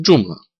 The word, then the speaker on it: Joomla: W języku polskim wymawiamy je Joomla.